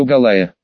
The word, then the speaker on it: Голая: Да вы правильно прочитали фамилию женщины, но неправильно поставили ударение, фамилия у нее Голая.